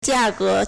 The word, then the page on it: 价格: 價格; jiàgé